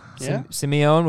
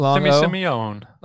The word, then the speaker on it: Simione: How would you pronounce this man's last name? His first name is Michael. His last name is S-I-M-I-O-N-E. – Simeone. – Simeone. We're going to go long O. – Simeone.